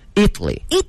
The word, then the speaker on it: Italy: Italy。那么如果说意大利的或者说意大利人，那么它的重音就发生了一个后移，叫 Italian。 – Italian，哎，那么说意大利是 Italy，意大利人 Italian，大家一起练一练这个词呢。